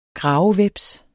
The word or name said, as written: gravehveps